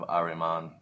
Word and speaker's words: Ahriman: So is black magic of Ahriman? It is Ahriman, right? Am I saying that right? – Yes, black magic of Ahriman.